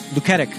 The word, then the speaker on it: Luketic: Luketic.